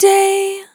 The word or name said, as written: Short Day